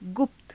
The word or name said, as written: gupta